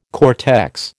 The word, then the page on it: cortex: American English